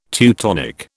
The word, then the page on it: Teutonic: British English